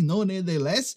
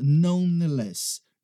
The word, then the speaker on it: nonetheless: Então, escreve nonetheless, né? Você vai ler assim, nonetheless, mas a gente simplesmente fala nonetheless, nonetheless, nonetheless.